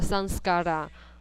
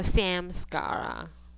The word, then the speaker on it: Sanskara: Sanskara，叫 Sanskara，不要说 Samskara，这是瑜伽老师所发音出来的梵文，是 Sanskara， Sanskara 是 Sankarta。